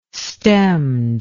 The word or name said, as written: stemmed